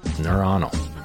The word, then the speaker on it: neuronal: And they contain the nerve cells, the non-neuronal cells, or neuronocells. I can never pronounce that one correctly. Neuronal. It's a fun word to look at.